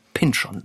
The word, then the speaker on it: Pynchon: Aber wir wissen nun immerhin, dass er Thomas Pynchon sagt. – Das heißt, in dieser Serie hat man ihn so ausgesprochen. Ja, es hat sich aber anscheinend, würde ich sagen, 20 Jahre ist es jetzt her, nicht groß rumgesprochen.